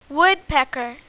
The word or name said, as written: woodpecker